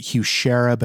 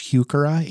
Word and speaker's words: Heuchera: So some people will pronounce it Heuchera, but it's Heuchera, H-E-U-C-H-E-R-A.